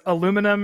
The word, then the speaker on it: aluminum: In the like cartoon version of this, it pans across all those metals and there is aluminum, and right next to it is aluminium. They have two. – Oh, one for the British pronunciation and one for the American.